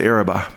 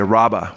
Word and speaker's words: Araba: Some pronounce it Araba, but the Araba, which would be the desert area that would be south of the 21st century.